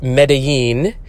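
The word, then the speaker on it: Medellín: I incorrectly was pronouncing it Mendelin, but it is Medellin.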